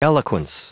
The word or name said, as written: eloquence